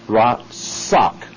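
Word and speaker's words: ratsak (רצח): It's pronounced ratsak. It comes out R-A-T-S-A-C-H.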